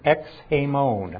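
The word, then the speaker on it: Ex hamon (ἐξ ἡμῶν): Ex hamon. That's how you say it in Greek.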